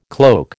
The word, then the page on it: cloak: American English